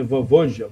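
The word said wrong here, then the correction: Vojvoda: Vovoja Voivode. Voivode, é.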